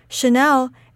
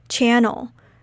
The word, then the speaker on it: Chanel: Chanel is pronounced like Chanel with a sh sound rather than a ch sound. Back in the days, I used to pronounce it like channel.